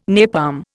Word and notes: napalm: Indian

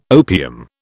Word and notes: opium: British English

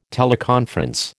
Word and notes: teleconference: American English

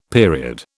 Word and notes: period: British English

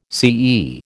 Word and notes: CE: American English

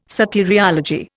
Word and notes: soteriology: Indian

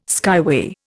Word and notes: skyway: Indian

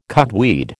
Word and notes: cudweed: American English

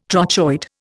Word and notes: trochoid: Indian

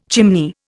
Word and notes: chimney: Indian

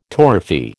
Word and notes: torrefy: American English

